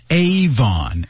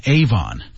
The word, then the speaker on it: Avon: Avon A-V-O-N Avon 注意读音 Avon、 Avon、